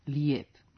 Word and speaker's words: lijep: Ekavskom dugom e, koje potiče od dugog jat, odgovara i ekavsko ije. Prema tome, pravilan ijekavski oblik je lijep.